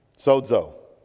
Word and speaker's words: sozo: Sozo. Tell me you can't say that word. Say it. Sozo. It's so with a D sound at the end.